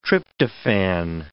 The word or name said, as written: tryptophan